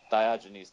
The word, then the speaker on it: Diogenes: Diogenes, thank you.